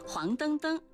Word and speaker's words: 黄澄澄: 黄澄澄属于叠词结构，那么黄澄澄的澄是不是它的第三种读音呢？不是的，黄澄澄属于叠词结构，后两个叠字澄澄遵循汉语的语音习惯发生变调，读作音平澄，因此整体注音是黄澄澄，而非原调的黄澄澄或是黄澄澄。